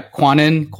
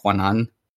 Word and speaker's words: Cuanan: It's pretty close. So, yeah, Cuanan. So if we're Cuanan. Talking like the like Tagalog pronunciation, it's probably like Cuanan.